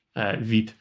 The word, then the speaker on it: Vite: I know it's French, Vite.